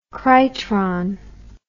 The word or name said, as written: krytron